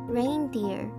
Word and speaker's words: reindeer: 这个字的发音和下雨这个字是一模一样的，都是长音 a, a 的音，所以这个字念成 reindeer, reindeer。